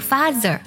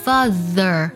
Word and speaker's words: father: 不管是 mother 还是 father，要特别注意 th 音呢。我们在读的时候要咬舌，不要读作 father 和 mother，而是 father mother。